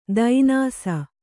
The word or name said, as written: ದೈನಾಸ